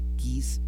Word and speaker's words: Kees: Si ustedes luego quieren buscar más información o música del maestro, tienen que poner K-E-E-S, que es Boeke, así se escribe su nombre, que es Boeke, Kiss Bech.